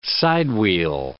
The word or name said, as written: side-wheel